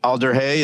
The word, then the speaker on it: Alder Hey: Yeah, well, we'll definitely link your efforts for, I think, Alder Hey, is that how you say it? – Yeah.